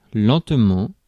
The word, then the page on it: lentement: [lɑ̃.tə.mɑ̃]; France (Paris)